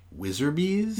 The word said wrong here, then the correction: Wizrobes: I think you might be talking about Wizrobes.